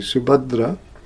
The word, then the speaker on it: Subhadra: This is not Subhadra, the middle one in the Jagannath deities. That has a long A, this is a short A. Subhadra against Sangramajit, Sumitra against Suratta, and the two Gadas against each other.